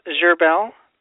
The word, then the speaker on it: Gherbil: It's Gerbil. – How do you spell that? – G-H-E-R-B-I-L. – B-I-L. G-H-E-R-B-I-L.